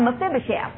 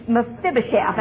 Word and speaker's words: Mephibosheth: Now, our study tonight has to do not with Jonathan, but has to do with his son, Mephibosheth. And it took me forever to learn how to say that. I'm so proud of it. Mephibosheth, and I will not say it wrong.